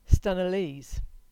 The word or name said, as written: Stenalees